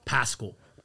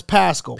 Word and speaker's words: Paschall: Now, how do I pronounce the last name? Pascal? – Pascal. Pascal. Yes. Okay. – So P A S C H A L L. Pronounce Pascal. Pascal.